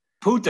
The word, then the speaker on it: Pulte: P-U-L-T, is it Pulte or Pult? – Pulte.